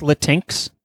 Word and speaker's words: Latinx: Is it supposed to be pronounced Latinx? – It is now.